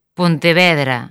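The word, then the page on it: Pontevedra: Galician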